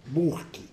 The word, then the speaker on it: Burke: É o ano de 1790, quando um escritor inglês, Edmund Burke, escreve-se Burke. Eu tenho que dizer isso porque lá no Brasil a gente pronuncia às vezes os nomes ingleses diferentes de vocês. Burke, que era um salafrário, segundo Marx.